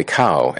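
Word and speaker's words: chao: Uh, pronounced cow, you know, holy cow as in uh, a cow, a C H A O, a cow representing a single unit of chaos.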